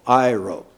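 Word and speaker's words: iro (αἴρω): Okay, English letters would be A-I-R-O, long O, iro.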